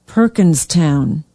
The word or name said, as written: perkinstown